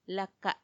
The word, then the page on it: laka: Macuiltianguis